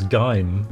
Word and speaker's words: Geim: Geim? G-E-I-M. – There's been lots of different spelling pronunciations of that name on the news this week. I think it's Geim.